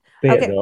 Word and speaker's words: Fayetteville: Am I saying this correctly? – Fayetteville, okay. – Yeah. – Fayetteville. Yeah, okay.